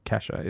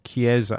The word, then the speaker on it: Kiesza: Kesha? No, not Kesha. It's not Kesha. It's Chiesa or Chiesa. Chiesa, K-I-E-S-Z-A.